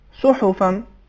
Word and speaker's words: صحفاً: حرف حا پر پیش ہے زبر نہیں ہے صحیح تلفظ کیا ہے اس کا صحفاً